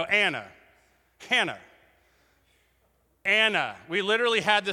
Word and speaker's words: Anna: Like, you mean Hannah? No, the H is silent and actually not even written. Her name is Anna.